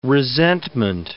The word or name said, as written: resentment